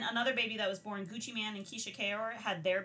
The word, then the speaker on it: Gucci Mane: We all pronounce it Gucci Mane. – Okay. – But in the South, Mane is how they pronounce it. Mane. – Oh, okay. M-A-N-E. – Okay. – But it is Gucci Mane. That's what you're going to say. Don't say Gucci Mane because you might get your ass beat.